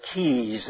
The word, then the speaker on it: cay: There are about 700 islands, or keys, as they call them. Small islands are called keys. That's spelled C-A-Y.